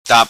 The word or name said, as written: Tap